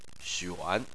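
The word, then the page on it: xuan: tone 3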